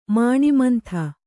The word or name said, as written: ಮಾಣಿಮಂಥ